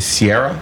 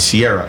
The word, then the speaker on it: Sierra: They call me like Ciara or Saria or whatever, but it's Sierra. And I always tell people it's like Sierra, the mist or the mountains.